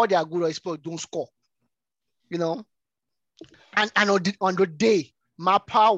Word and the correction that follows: Maupay: was, I don't know if I'm pronouncing his name pretty well. – Maupay. Maupay.